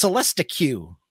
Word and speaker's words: Celestiq: Am I saying that? It's Celestiq. – Celestiq. – Okay. I put Celestiq. My emphasis on the wrong. – No, when you see it, when I first saw it, it's like, okay, it's spelled Celestiq. So when I first saw it, I was like, oh, it's Celestiq. Don't think about it too hard. It's Celestiq.